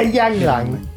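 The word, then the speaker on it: ylang-ylang: Yeah, ylang ylang.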